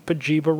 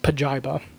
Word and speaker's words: Pajiba: Did you know that we've been saying Pajiba wrong forever? – Pajiba? – Pajiba. – Oh. – I'm still going to say Pajiba, but I like Pajiba better. – It's actually pronounced Pajiba.